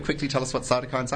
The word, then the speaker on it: cytokines: Is that how you pronounce cytokines? – Yep. Mm-hmm.